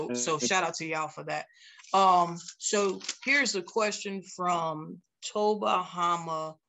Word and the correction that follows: Tom Bahama: I hope I'm saying this right. – Oh, yeah, Tom Bahama.